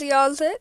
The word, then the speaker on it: Yahzick: His name is Yazik. That's spelled Y-A-H-Z-I-C-K.